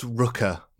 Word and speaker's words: Rooker: It's pronounced Rooker, Michael Rooker.